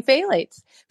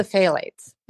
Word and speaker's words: phthalates: It won't phthalates is spelled P H T H A L A T E S, and it never says that.